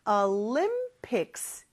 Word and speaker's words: Olympics: 근데 발음이 조금 달라요. Olympics 이렇게 limb 여기가 강세가 있으니까 꼭 그거 지켜서 발음해 주세요.